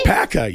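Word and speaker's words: Waupaca: You said it right. – Really? – Wapaka, yes, no. That is correct. Yeah. – I was over here saying Wapaka, Wapaka. – Wapaka, Wisconsin. That is exactly correct.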